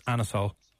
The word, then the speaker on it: anisole: He says it's Anisole. That's how it's pronounced.